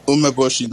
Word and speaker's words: Umeboshi: It's Umboshi 9. Umeboshi 9. Or, I'm sorry if I'm mispronouncing. – whoever was on there. – Man, nigga in Discord, man. – You're not. You're not mispronouncing.